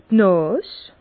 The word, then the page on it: d' Nàs: Bas-Rhin; Herrlisheim